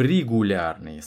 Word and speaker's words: регулярный: Заручившись одобрением детей Аркадия Исааковича, Екатерины и Константина, мы превратили фестиваль в регулярный или в регулярный с ударением на первом слоге.